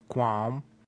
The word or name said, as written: quam